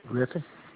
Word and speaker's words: Griffin: Clyde Griffin. – And spell that last name, please. – G-R-I-F-F-I-N.